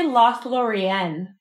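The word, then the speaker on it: Lothlórien: And today we are reading Chapter 6, Lothlorien, if that's how you pronounce it. – It's how I say it. Or wait, I put the emphasis slightly different, I'm realizing. I say Lothlorien. – Lothlorien? – Yeah.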